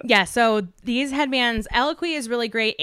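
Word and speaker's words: Eloquii: I don't know how to say the word, which, you know, when you're like, you think a word a lot and then you have to say it out loud. It's the brand ELLOQUI. I don't even know how to spell it. – Eloquy. Eloquy. Yeah.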